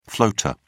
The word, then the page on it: floater: British English